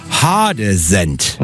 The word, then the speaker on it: Heidesand: In der Südheide und rund um Hannover und Braunschweig, also den Regionen, die ja angeblich dialektfrei sind, spricht man Heidesand anders aus. Es heißt da nämlich Hadesend.